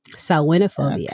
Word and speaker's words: Samhainophobia: Samhain. Excuse me. I'm saying it wrong too. I'm telling people how to say it and I'm wrong too. Samhain. – Samhainophobia.